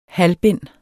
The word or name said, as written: halvbind